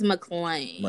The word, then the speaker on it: McClain: That's just for Elijah McCain. Is it McCain or McClain? – I think it's McClain.